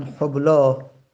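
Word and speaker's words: حبلی: یا مانند مثلا حبلا، حبلا، یک آخرش یا هستش، روی یا الف که دیگه یا تلفظ زده نمیشه.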